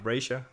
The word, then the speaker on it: Brazier: Hvordan udtaler man det? – Det, det ved jeg. jeg. kalder ham Brasher. – Ja, Det, det sagde jeg nemlig også. Og jeg, men jeg har også hørt andre udtaler, så jeg var, men vi, vi går med det.